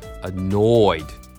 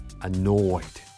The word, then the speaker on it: annoyed: これはもうストレスの noy に苛立ちを込めましょう。ね、で、二重母音の多いだからね。多い。多い。多い。ここね、かなり丁寧めに発音するとより苛立ち感が表現できると思います。 annoyed。 annoyed。